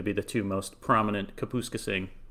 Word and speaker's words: Kapuskasing: So Kirk McCaskill, born in, we're going to need a pronunciation check here. – Yeah, I think that it is Kapuskasing. Kapuskasing.